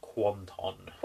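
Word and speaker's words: Kwanten: Kiss is batshit fucking mental, and it's got Ryan Kwan in it. Ryan Kwan? – Quanton. – Quanton, I can never pronounce what his surname is.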